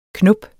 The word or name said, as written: knop